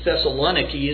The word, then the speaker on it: Thessaloniki: somewhere along the shores of this port of Thessaloniki, as it's pronounced today.